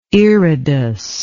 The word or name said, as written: iridous